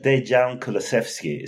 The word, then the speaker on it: Dejan Kulusevski: I think it might be that Eastern Europe thing, but Dejan Kulusevski is how we pronounce it. So it's basically how it sounds.